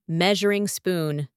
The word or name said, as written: measuring spoon